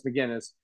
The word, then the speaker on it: Magennis: It's Magennis.